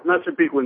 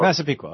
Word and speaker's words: Massapequa: Massapequa, New York. Am I saying it right? – Hey. How you doing? – Great. What's up? – That's Massapequa, New York. – Massapequa, okay.